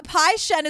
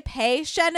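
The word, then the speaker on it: Pei-Shen: Pai Shen is right. Sometimes people say Pei Shen in this, but I Googled and it can go either way. So I think it's Pai Shen. – How do you say the last name? – Qian. – Oh, Q-I-A-N, Qian.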